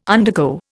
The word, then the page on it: undergo: Indian